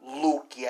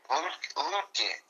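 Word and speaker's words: Luetge: Lutke, I think it's L-U-E-T-G-E, I think. – Lutke.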